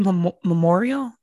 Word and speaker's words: memoriam: Like it's a pandemic and I get like, they had those memorial. I'm saying that wrong. It's a memoriam.